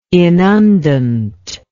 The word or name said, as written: inundant